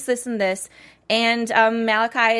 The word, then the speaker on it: Malachi: And uh, it's that's how I say it, right? Malachi, did I say it right? Malachi. Yeah. yeah. yeah.